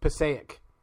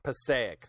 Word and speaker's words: Passaic: Passaic. – Passaic. – I'm from New Jersey. I can help. – I knew you were Passaic, New Jersey. – Yep. – So testicle eating fish. – Passaic.